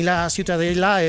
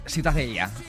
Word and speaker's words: Ciutadella: ¿cómo se pronuncia? – La Ciutadella. – Ciutadella. Bueno, pues Ciudadela también en castellano. – Ciudadela, sí. Bueno, pues eso, ha quedado clarísimo. Pero, ¿para qué se meten? – ¿Tú cómo lo pronuncias? – Ciutadella. – Muy bien.